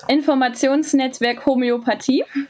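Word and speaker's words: Informationsnetzwerk Homöopathie: And that is, of course, Information Netzwerk Homeopathy. Can you help me out with that pronunciation, please? – Yeah, so in German, it's Informationsnetzwerk Homeopathie. – Oh, it sounds much better.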